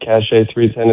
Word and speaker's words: Cache: Is that the best way to pronounce it? Welcome to the show. – Hi, well, thank you very much for having me. Yes, that would be the best way to pronounce it. Cache 310 is correct. – C-A-C-H-E with the accent over there, 310.